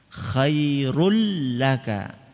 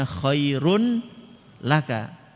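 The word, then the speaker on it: Khairul laka (خير لك): Nun tanwinnya tadi dimasukkan ke huruf lam sehingga tanwinnya enggak kelihatan. Khairul laka. Enggak kelihatan kan? Bukan bacanya khairun laka. Tanwinnya itu seakan-akan kayak dihapus. Sebenarnya bukan dihapus karena tulisannya masih ada, cuman cara bacanya itu dimasukkan ke huruf sesudahnya.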